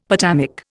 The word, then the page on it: potamic: Indian